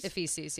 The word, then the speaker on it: Ephesus: And then there was a statue of a bust of Diana of Ephesus. I think I'm pronouncing that correctly. – Yeah, I think you're right. Ephesus, yeah.